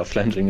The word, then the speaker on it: Flanging: Äh, ja, Flanging ist schon richtig ausgesprochen.